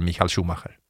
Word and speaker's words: Michael Schumacher: Alla säger Michael Schumacher.